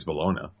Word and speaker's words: bologna: It ends with an A, so I gotta say it's Bologna. – Yeah. – Well, it's actually Bologna. It's one of the pronunciations is Bologna, because it's to rhyme with pony.